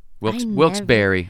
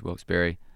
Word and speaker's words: Wilkes-Barre: Wilkes-Barre. I'm sorry. – Okay, I was like, I have been saying that wrong. – Yeah, I think it's Wilkes-Barre, but touring together.